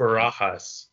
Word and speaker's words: Barajas: You got Rod Barrios. I think that's how you pronounce it. Rod Barajas. – Barajas, yes.